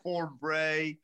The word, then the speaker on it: Thornbury: I love the fact he went to Thornbury. That's spelled Forn-Bury. Thornbury. Castle School.